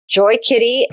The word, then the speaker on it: Joy Kitty: It's Joy Kitty. Okay. Joy is in joy to the world, not Joyce with a C-E on the end, which a lot of people want to add, but sure.